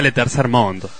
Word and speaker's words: Le Tercer Monde: La Tercer Mon se escribe La Tercer Mon de, con al final, pero ¿cómo se pronuncia? – Se pronuncia Le Tercer Mon.